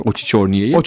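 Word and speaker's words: Oçiçorniye'yi (Очи чёрные): İnşallah doğru söylemişimdir Oçiçorniye'yi. Chorniye güzel, telaffuz mükemmel.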